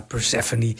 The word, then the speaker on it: Persephone: Ik zei het net eigenlijk niet goed. Het is geen Persephone natuurlijk, maar Persephone. Trapezoid, spreek je het volgens mij uit.